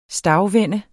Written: stagvende

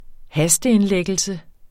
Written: hasteindlæggelse

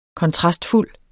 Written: kontrastfuld

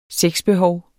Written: sexbehov

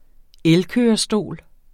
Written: vinterfoder

